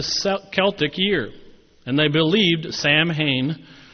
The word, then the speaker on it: Samhain: The holiday was called Samhain, spelled S-A-M-H-A-I-N. It looks like Sam Hain. Every time I see it, I look at Sam Hain, but it's Samhain.